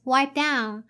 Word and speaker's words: wipe down: There's a linking between wipe and down. So link the two words together. Wipe down. Wipe down. Instead of wipe down. So you don't have to pronounce the p sound here.